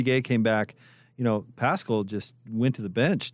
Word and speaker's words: Paschal: And I think a huge part of his success is his name, Paschal, rhymes with rascal.